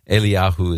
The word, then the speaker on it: Eliyahu: It's, and Eli is how, or Eli, or Eli, Eliyahu is how you say Elijah.